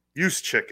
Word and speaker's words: Juszczyk: Juszczyk is how you say it. Looks like Juszczyk.